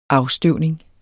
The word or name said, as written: afstøvning